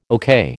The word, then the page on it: okay: American English